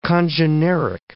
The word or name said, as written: congeneric